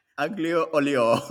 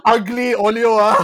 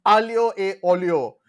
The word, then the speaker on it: aglio e olio: Aglio olio. – It's not ugly olio, please. It's aglio e olio.